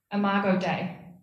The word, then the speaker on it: Imago Dei: Imago Dei. Did I say it right? – Yeah. – Yeah.